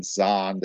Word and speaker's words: Zahnd: Now, Brian Zand made a great comment, Z-A-H-N-D, Brian Zond is probably the way it's pronounced, Brian Zand.